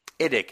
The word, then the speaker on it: IDIC: IDIC, if you're somebody that actually pronounces it, rather than spelling it.